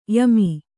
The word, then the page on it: ಯಮೆ: yame